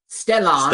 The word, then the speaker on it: Stellan: Isn't he a Slosgard? – Yeah, I can't even say his name. S-T-E-L-L-A-N. Stellan. – Stellan. All right. – S-K-A-R-S-G-A-R-D.